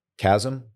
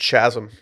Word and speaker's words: chasm: Like a little chasm, if you will. – It's pronounced chasm. – A little chasm, if you will.